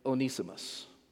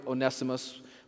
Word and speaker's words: Onesimus: a runaway slave named Onesimus. Now, I always learned Onesimus, but I've got this neat little thing in my computer that gives correct pronunciations, and I'm relying on it. Okay. Onesimus.